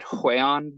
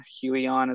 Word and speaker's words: Huion: I found out from somebody that speaks Mandarin that it said Huion, but it's Huion. Huion is the way we Americans say it.